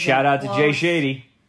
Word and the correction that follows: Shetty: Is that how you say it? – Jay Shetty. – Shetty. – Shetty. Yes.